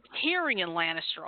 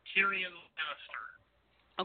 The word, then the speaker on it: Tyrion Lannister: Tyrion Lannister. – Okay, I know how to spell it. I just forgot how to pronounce it. It's Tyrion. I know that much already. – Yeah, Tyrion Lannister.